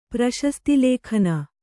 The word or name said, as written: ಪ್ರಶಸ್ತಿಲೇಖನ